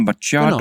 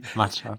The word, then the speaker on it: Macchiato: Latte Macchiato. Genau. – Machado. – Kennst du die, Alter, die so konsequent alles falsch aussprechen? Sowas wie Machado.